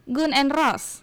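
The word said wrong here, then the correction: Guns N' Roses: Bacanya bukan Gun. – Oh, Guns. Guns and Roses.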